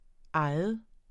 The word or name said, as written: egen